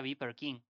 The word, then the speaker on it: BPRKNG: con está escrito con siglas, dice BPRKNG, que se pronunciaría Viper King.